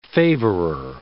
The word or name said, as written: favorer